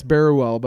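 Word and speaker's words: Barruel: Can you imagine Augustin Berruel, I believe is how you say his name. – Yeah, it's Berruel, but I'm going to just say Augustin.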